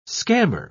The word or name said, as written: scammer